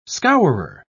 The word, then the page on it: scourer: British English